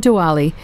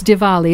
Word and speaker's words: Diwali: It's not Diwali. It's Diwali, isn't it? That's the proper way to say it.